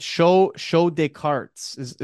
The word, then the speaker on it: Show De Cartes: I should also mention right off the hop that you are part of the, is it Show Descartes is how you pronounce it, right? – And that's it's French for card show.